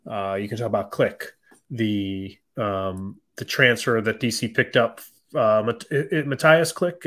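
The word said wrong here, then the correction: Mateusz Klich: is that who it is, Danny? – I think it's Matouche Klich.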